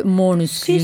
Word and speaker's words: Måneskin: Cioè, quando è Maneskin, perché è facile per tutti, poi però se glielo chiedi dicono, ah no, insomma si legge Måneskin.